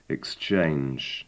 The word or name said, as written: exchange